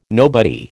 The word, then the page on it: nobody: American English